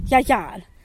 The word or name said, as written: yagyal